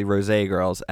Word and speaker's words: Rosé: It's spelled Rose Girls, but it's obviously Rosé Girls, @the_RoséGirls.